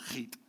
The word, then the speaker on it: chet: You have to suck it back in the back of your throat. It's spelled S uh, C H E T, but the CH is the sound from Bach, Hach, and it's uh, eighth, height, height, something like that.